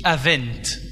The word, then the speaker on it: Avent: Ça s'écrit A-V-E-N-T. Ça se dit Avent.